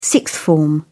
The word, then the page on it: sixth form: British English